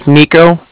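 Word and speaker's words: Niko: It's Nico, that's N-I-K-O. – Okay. – And then the Bank of Cardiff is spelled C-A-R-D-I-F-F, as in Frank.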